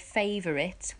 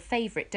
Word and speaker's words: favourite: Now, when we say the word favourite, we normally say sort of favourite, don't we? – Yeah. – But we have to use all 3 syllables, favourite, because of the notes, yeah? We're singing 3 notes. So instead of favourite, it's favourite. So the middle note needs to be the same length as the other two.